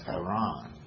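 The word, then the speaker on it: Harran: was spelled in two R's, H-A-R-R. A-N, and it's pronounced Haran. And Abram's brother was named Haran, H-A-R-A-N.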